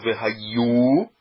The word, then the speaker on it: Vehayu: Então, qual é a sílaba tônica do Vehayu? É no Yu. É Vehayu e não Vehayu.